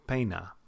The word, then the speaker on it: pena: That sound, A, A, is different than A. Pena is different than table. If you listen really closely, there's more of a distinction between those two vowel sounds in the Spanish version than there is in the standard American version.